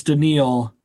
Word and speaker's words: Danielle: Let's move on to wide receiver, because have you read, by the way, the commenters are telling us how to pronounce it. It's Daniil.